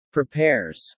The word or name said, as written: prepares